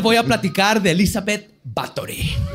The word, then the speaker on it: Báthory: La condesa Elizabeth Báthory de Exed, porque leí, se pronuncia Báthory aparentemente, nació en Nibrator, en Hungría, en lo que ahora se conoce como Eslovaquia y Rumania, el 7 de agosto de 1560.